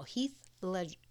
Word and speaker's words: Ledger: And also, there's the Joker in The Dark Knight, and that's the Heath Ledger's Joker. And Heath Ledger's... – Yes. – Heath Leather? – Now, If I were to buy a couch... – First of all, Heath Ledger...